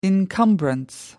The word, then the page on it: encumbrance: American English